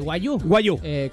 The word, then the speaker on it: wagyu: ¿Wagyu? ¿Cómo se dice? – Wagyu.